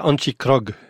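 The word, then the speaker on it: Antjie Krog: Alors, justement, l'écrivaine sud-africaine, afrikaner, comment on prononce Antje Krok? – Antje Krok, si vous voulez bien prononcer, mais on va dire Krok.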